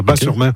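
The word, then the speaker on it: Batz-sur-Mer: Et surtout, la crêperie s'appelle le Dervin à Bas-sur-Mer, qui font les meilleures crêpes de la Bat. – On prononce Bas, le T ne se prononce pas. Bas-sur-Mer.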